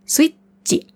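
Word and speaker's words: Switchi (スイッチ): Switchi. Switchi. Auch hier wieder mit kleinem Zu, damit das Chi hart ausgesprochen wird. Das ist das Chi. Switchi.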